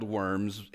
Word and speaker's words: Worms: It's spelled Worms in our English, but it's pronounced Worms.